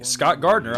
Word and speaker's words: Scott Gardner: And hey, you can friend me, Scott Gardner, on Facebook too. My name is spelled S-C-O-T-T-G-A-R-D-N-E-R.